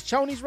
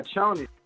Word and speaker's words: Chonies: Am I saying that right? Yeah. Chonies? Is Chonies right? – Yeah, Chonies. Chonies.